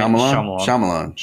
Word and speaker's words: Shyamalan: I don't know how to say his name right. – M. Night Shyamalan. – Shyamalan.